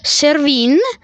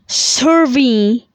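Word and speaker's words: Cervin: E eh, appunto è nella catena del Cervino, del Bonsuel Servino, Servin, perché è compreso con anche la catena svizzera. Allora, il Cervino in francese si pronuncia Servin, Servin e in tedesco Matterhorn. Matterhorn.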